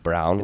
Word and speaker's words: Braun: Christian Brown, but it spells out Braun, but it's pronounced Brown, which I don't understand. – Braun. – But it's German.